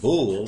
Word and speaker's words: Bul: However, we're in right now the 8th month of God called Bul, the third day. And that word, and that's, it's pronounced B-O-O-L, Bul, even though it's spelled Bol.